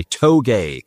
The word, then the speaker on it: touge: And on September 20th and November 16th, there's a group that puts on sort of track days called Corner Exit, and they are putting on an event called a toge. That is how I pronounce it, T-O-U-G-E.